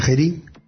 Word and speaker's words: Geary: Pues sí, vamos a hablar de qué software, de un software llamado Geary, escrito así, creo que se pronuncia Geary, G-E-A-R-Y, que es un cliente de correo ligero y con muchas funcionalidades, y creado especialmente para el entorno de escritorio Genome.